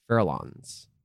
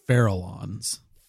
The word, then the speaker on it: Farallons: Am I saying that right? Farallons. F-A-R-A-L-L-O-N-S. Farallons. Farallons. – I bet it's Farallons.